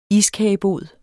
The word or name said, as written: iskagebod